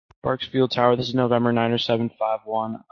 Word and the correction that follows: Bakersfield: First of all, the pronunciation is Bakersfield. Second of all, are you under radar service? Did Bakersfield Approach switch you? – November 9751.